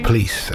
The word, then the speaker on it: Pleis: Jack Kenneth Pleese, it's spelled P-L-E-I-S, and he is quoted as saying it's pronounced Pleese, as in fleece or grease.